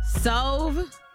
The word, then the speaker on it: Sove: Cinco, Sove. Is that how you say it? Like S-O-V-E, like stove without the T.